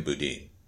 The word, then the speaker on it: Boudin: Boudin, however he pronounces his name. He pronounces his name Boudin.